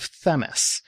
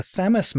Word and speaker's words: Themis: It was the launch of Themis, not Themis. I actually found a video with, I think, the PI who pronounced it Themis.